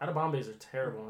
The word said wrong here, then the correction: edamame: He means edamame.